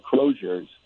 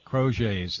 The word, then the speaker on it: Crozet's: I don't think Crozet's, and I might be mispronouncing it. It looks like Crozier's. – Crozier's, yeah. – Crozet's, that's the right way to say it.